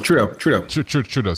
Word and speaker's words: Trudeau: Thoreau? Justin? – Trudeau, excuse me.